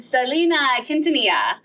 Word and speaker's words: Selena Quintanilla: Selena Quintanilla.